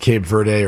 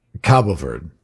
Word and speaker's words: Cabo Verde: I'm saying, by the way, it's pronounced both ways. It's pronounced Cape Verde or Cape Verde, but the Portuguese pronunciation is Cabo Verde.